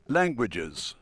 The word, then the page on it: languages: British English